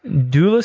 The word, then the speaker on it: doula: That provides doula services. Am I saying that right? – Yes, you are.